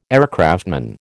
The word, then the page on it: aircraftman: American English